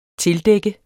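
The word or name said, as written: tildække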